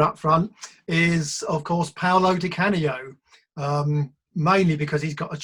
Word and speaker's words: Paolo Di Canio: But Paolo Di Canio. Paolo Di Canio.